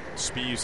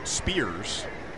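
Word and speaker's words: Speas: Spees says the worst pronunciation he gets is Spears, which isn't all that far off on one hand, but then again, where does the R come from?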